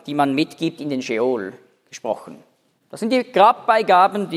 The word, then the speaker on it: Scheol: Nicht Sheol, sondern Sheol. Mit Stimmabsatz.